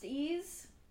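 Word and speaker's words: Ys: The album is called Ease, pronounced Ease, by Joanna Newsom.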